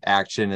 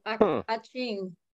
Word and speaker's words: Ak-Chin: So I highly recommend Ak-Chin. It's it's spelled out A K dash C H I N. – Oh, Ak-Chin.